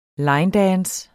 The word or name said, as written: linedance